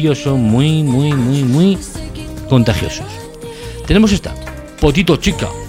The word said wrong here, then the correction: Potito Chicha: No, perdón, es Potito Chicha. Cuidado con la pronunciación, ¿eh? Esto es muy importante. Porque Potito Chica es una cosa y Potito Chicha es otra. Porque, por ejemplo, Potito Chicha significa comida para bebés con carne.